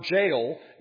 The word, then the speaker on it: gaol: If you visited England, you might know that they spelled the word jail, G-A-O-L, not the way we spell it. And so when I saw this Hexham, G-A-O-L, what is that?